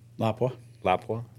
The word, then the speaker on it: Lapua: Lapua. – Lapua.